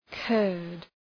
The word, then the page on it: Kurd: {kɜ:rd}